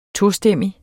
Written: tostemmig

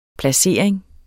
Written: PLACERINGEN

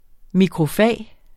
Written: MIKROFAG

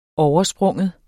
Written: overspringe

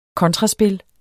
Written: kontraspil

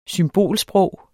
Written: symbolsprog

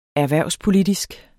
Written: erhvervspolitisk

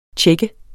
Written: tjek

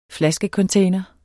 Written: flaskecontainer

